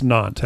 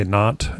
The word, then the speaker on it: Nantes: Yeah, it's Nantes, actually. The English people say Nantes in France.